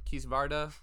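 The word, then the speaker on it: Kisvárda: Then last game we got is Kisvadra, Kisvarda. Wow, butchered that the first time.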